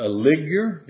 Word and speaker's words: ligure: And the third row, a ligure, is the way I believe that's pronounced, and an agate, and an amethyst. In the fourth row, that's pronounced beryl, and an onyx, and a jasper, they shall be set in gold in their enclosings.